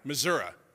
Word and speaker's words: Missouri: I said it right, Missouri.